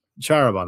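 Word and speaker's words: Čaroban: Charaban, that's it. I always forget how to pronounce that C.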